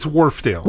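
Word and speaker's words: Wharfedale: Is it Wharfendale? How do you say it? – It's Wharfdale. – Wharfdale. Sorry, I added an extra letter.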